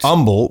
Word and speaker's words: Humble: just like Houston doesn't have the H. It has the long U, the Houston, and Humble, so the U there.